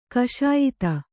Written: ಕಷಾಯಿತ

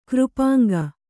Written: ಕೃಪಾಂಗ

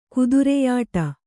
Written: ಕುದುರೆಯಾಟ

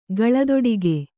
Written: ಕಾಲ್ದೊಡಿಗೆ

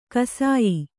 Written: ಗೋಸಾಯಿ

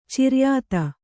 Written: ಚಿರಾಯಿತ

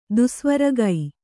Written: ದುಸ್ವರಗೈ

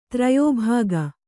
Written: ತ್ರಯೋಭಾಗ

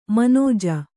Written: ಮನಜೆ

